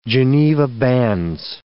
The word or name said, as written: Geneva bands